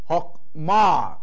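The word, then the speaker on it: hokma (חכמה): Hokma，那你看看第一个音是短母音 ok，所以这里是 hok，这个是整个是一个 close syllable，所以这个是 ok，是 hok， hokma wisdom 的意思 hokma。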